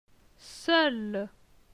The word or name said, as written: Seul